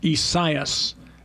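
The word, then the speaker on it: Isaias: Esaias, the A-I after the S, okay. are one syllable by rules of language.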